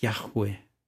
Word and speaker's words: Yahweh: The word YHWH sounds like Yahweh. Yah, you inhale, weh, you exhale.